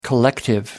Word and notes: collective: American English

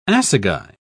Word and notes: assagai: British English

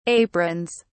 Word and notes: aprons: British English

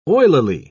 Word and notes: oilily: British English

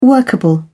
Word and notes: workable: British English

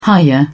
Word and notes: hiya: British English

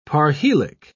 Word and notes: parhelic: British English

